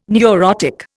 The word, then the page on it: neurotic: Indian